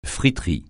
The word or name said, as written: friterie